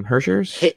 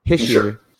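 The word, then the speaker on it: Hischier: The only other NHL news that I found for this week was Nico, I can never say his name, Hershers? – Hishier.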